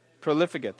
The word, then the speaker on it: profligate: and the profligate. I think that's how you pronounce it. Profligate, thank you.